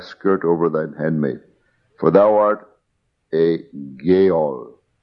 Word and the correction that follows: Goel: Thou art a near kinsman. I'm sorry, thou art a Goel. I pronounced it wrong, Goel.